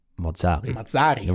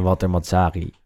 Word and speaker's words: Mazzarri: En Mazzarri. Walter Mazzarri. En af en toe moeilijk om die klemtoon te leggen.